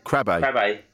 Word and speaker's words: Krabbe: So Jackson was diagnosed with, how do you pronounce it? Is it Krabbe. – Krabbe.